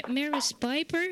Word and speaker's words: Maris Piper: Maris Piper, zeg ik dat goed? – Ja, je zegt het goed inderdaad.